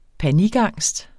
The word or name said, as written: panikangst